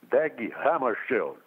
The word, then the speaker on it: Hammarskjöld: Dag Hammarskjöld.